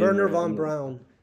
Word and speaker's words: Wernher von Braun: Wernher von Braun.